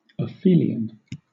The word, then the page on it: aphelion: British English